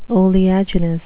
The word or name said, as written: oleaginous